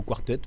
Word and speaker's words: quartet: Oui, oui, quartet. Nous, on dit plutôt quartet, mais ça, ça va aussi, Barbershop Quartet.